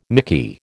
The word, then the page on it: mickey: American English